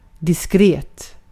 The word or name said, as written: diskret